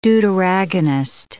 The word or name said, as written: deuteragonist